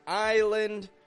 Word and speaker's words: island: There is an S in the word island, I-S-L-A-N-D. But I don't pronounce the S. I actually drop the S right out of there, and I use a tense I sound, I.